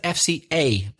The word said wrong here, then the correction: FCE: Be careful. ABCDE, FCE exam.